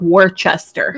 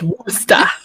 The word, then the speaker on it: Worcester: she made certain that I knew how to say it because the city looks like Worcester. – Mm-hmm. No, it's Worcester. Worcester.